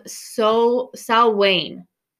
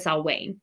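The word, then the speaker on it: Samhain: um, and it's a celtic tradition and it's actually called So Wayne, but it's spelled um, hain but it said Salwain.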